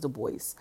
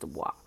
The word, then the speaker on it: DuBois: Miss Jeanette Dubois was, I thought that it was pronounced Dubois, but Mallory told me it's pronounced Dubois. So, people who grew up in the early 90s, we know exactly who she is.